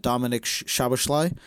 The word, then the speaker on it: Dominic Szoboszlai: They made a couple big signings that we've talked about already on this pod, Alexis McAllister and Dominic Szoboszlai.